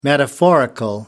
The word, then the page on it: metaphorical: American English